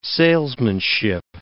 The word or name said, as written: salesmanship